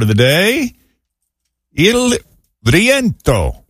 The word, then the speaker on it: rientro: It's spelled R-I-E-N-T-R-O, Il Rientro, if you can't roll your R's like I cannot.